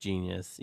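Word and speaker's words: jeen-yuhs: I watched the 3-part documentary to, I believe it's pronounced Genius, even though it's spelled weird. – Genius.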